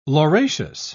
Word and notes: lauraceous: British English

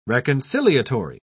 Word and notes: reconciliatory: British English

